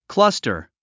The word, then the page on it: cluster: klʌ’stə r; クラスタァ